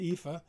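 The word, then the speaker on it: EAFE: That's how it's pronounced, EFA. Europe, Australia, and the Far East.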